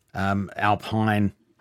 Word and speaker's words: Alpine: Well, McLaren, AlphaTauri, Alpine, is the correct way to pronounce it.